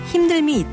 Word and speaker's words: 힘듦: 발음할 때도 삶이 힘들다, 만듦이 좋다, 힘듦이 있다처럼 리을 발음을 살립니다.